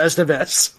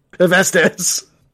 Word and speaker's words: Estevez: Every time mispronounces Estevez. – Yes. – Estevez. – Hevestes. – Hevestes. No! It's a Carlos Esteban.